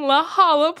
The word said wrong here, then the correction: La Jolla: Playhouse, and it is, it's what? La Jolla? – Yeah. – That should not be pronounced like that.